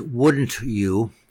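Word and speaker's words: Woody'n You: It's pronounced Wouldn't You, but at the same time, the Woody part is a tribute by Dizzy Gillespie to Woody Herman and his band.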